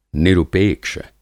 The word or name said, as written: निरुपेक्ष